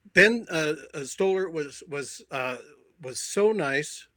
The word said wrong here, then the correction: Stiller: Did I get the name right? – Stiller, yeah, Ben Stiller, sure.